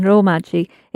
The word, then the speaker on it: romaji: And you'll notice it's a long ro, romaji.